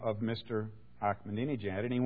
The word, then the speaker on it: Ahmadinejad: It is spelled, when you look at it phonetically, it would be Ahmadinejad. But the Farsi language that the Persian people speak, the people of Iran speak, it's pronounced Ahmadinejad.